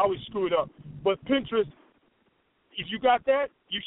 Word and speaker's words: Pinterest: Pinterest. – But y'all know P-I-N, say it for me.